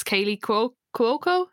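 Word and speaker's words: Kaley Cuoco: It's Kaylee Cuoco, is that how you say it? – Mm-hmm.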